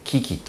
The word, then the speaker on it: QUI: QUI、 QUI と書いて、キキと読む